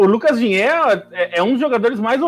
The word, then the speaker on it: Digne: O Lucas Digne, que tem o Digne, talvez, não sei como é a pronúncia em francês. – É mais Digne.